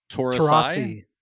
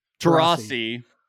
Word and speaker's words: Taurasi: So Sue Bird and is it Diana Taurasi? – Taurasi. – Terrible pronunciation.